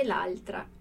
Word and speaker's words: l'altra: Tra una cosa e l'altra, ok? A gente vê o quê? Attenzione alla pronuncia qua. L'altra virou uma palavrinha só, né? Um som só.